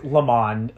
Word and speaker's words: Le Mans: So, in that sense, I have heard of Le Mans. – It's Le Mans, but no D.